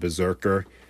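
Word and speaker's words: BRZRKR: It's called Berserker. Uh, not spelt at all like Berserker. Uh, every time I read out the name, I got to spell it. It's B R Z R K R.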